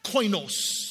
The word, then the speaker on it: koinos: The first part is it's pronounced this way. – Se pronuncia así. – Koinos.